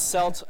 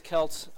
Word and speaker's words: Celt: Yeah, so Jean-Marc uses CELT, I use KELT. I mean, people... The actual, yeah, so the actual Celtic or Celtic people, you know, get also the both terms are used for the pronunciation of that, so I don't think it matters terribly much.